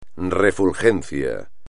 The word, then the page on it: refulgencia: espagnol